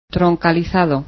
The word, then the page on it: trunking: Spanish